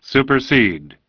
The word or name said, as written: Supersede